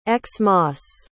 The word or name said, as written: x-mas